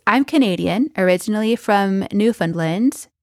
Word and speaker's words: Newfoundland: Newfoundland. – Newfoundland. – Sort of like some of the letters are sort of disappear.